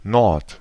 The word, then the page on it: Nord: /nɔʁt/